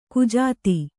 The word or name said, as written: ಕುಜಾತೆ